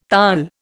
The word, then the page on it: Taal: Indian